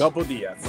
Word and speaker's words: Gobbo Diaz: Torniamo fra pochissimo ancora col nostro ospite di oggi, Miguel Angel Gobbo Diaz. Gobbo Diaz. L'ho detto bene. L'hai detto bene, l'hai detto bene, bravo.